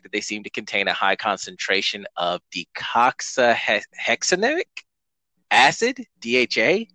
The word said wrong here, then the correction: docosahexaenoic: I'm not pronouncing that right. It's docosahexaenoic. D-O-C-O-S-A-H-E-X-A-E-N-O-I-C.